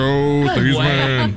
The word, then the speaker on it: Theismann: Joe Theismann, they said his name wrong. It's Theismann, but it's Theismann for the Heisman campaign at Notre Dame.